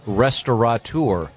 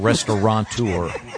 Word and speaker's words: restaurateur: I know that the most mispronounced is restaurateur. – Mm-hmm. – There's no N. It's not restaurateur.